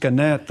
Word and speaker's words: Gannett: And I was in New York and I saw an article about, is it Gannett? The way it's pronounced, Gannett, the parent company to the Leaf Chronicle, how they were downsizing properties and taking the lease back.